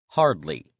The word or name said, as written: hardly